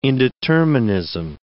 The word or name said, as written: indeterminism